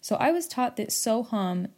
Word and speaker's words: Soham: Soham is spelled S-O-H-A-M. So it's spelled Soham. I was taught that in Sanskrit, H-A-M is pronounced hum instead of ham. You will hear some people pronounce it ham, ham.